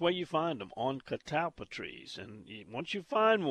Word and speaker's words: catalpa: Are you saying catalpa, C-A-T-A-L-P-A, or catabla, C-A-T-A-B-L-A? The actual word, the correct spelling is C-A-T-A-L-P-A, which would be pronounced probably catalpa. But, you know, country boys like Jeff, it's catalpa, catalpa worms. And they actually spell it C-A-W-A-T-A-B.